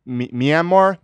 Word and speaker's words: Myanmar: Anyway, so yes, the British launched an amphibious offensive against the Japanese forces, the Imperial Army, in Burma, which is now Myanmar? – Myanmar? Yeah. – How do you pronounce it? – Myanmar.